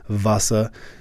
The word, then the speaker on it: Vasa: I think it's spelled W-A-S-A, but I think that is still pronounced Vasa.